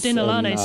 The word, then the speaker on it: Denalane: Denalane, sorry, I've been saying that wrong.